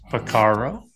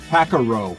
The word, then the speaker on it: Porcaro: Oh, you said it right this time. – yeah, you, you made me nervous. I was panicking. you typically you would say Pacaro. Yeah. or Caro, Pacaro. Yeah.